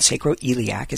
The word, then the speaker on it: sacroiliac: It's called sacroiliac is how you pronounce that.